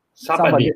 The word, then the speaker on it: Sabadell: é, a pronúncia em catalão seria Sabadell, em espanhol, com dois L no final.